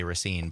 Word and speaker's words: Racine: Also had the smartness and the quickness to call out Racine, Wisconsin, and knows how native Raciners pronounce it even. – Even other people around the state often say Racine, but right. Racine, if you live there, is how you say it.